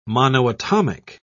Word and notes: monoatomic: British English

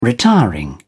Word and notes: retiring: British English

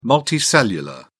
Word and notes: multicellular: British English

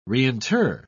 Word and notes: reinter: British English

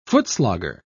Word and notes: footslogger: British English

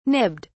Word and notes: nibbed: British English

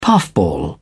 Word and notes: puffball: British English

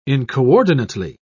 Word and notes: incoordinately: British English

